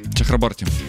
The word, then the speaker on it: Чахраборти: Чахраборти. – Да, какая разница. – Там на борте ударение. Как Саша Бортич проверочная.